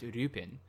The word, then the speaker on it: Lupin: Arsène Lupin. – Because, so like, in French, his surname is pronounced Lupin, but in Japanese, Lupin the Third's name is pronounced Rupin, which is closer to the English Lupin.